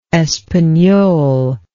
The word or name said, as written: espagnole